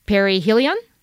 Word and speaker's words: perihelion: One is that Jupiter is in opposition, and then Jupiter is in, correct me if I say this wrong, perihelion? – Correct. Yes.